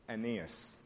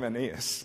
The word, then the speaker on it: Aeneas: And if we begin with, I find it really hard to pronounce this name as it should be pronounced. Aeneas. Thank you. That's good. Let's call him Aeneas.